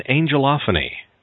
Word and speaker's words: angelophany: What is an angelophany, and am I pronouncing that correctly? – Yes, you are, Art, and that's literally the perception of an angel.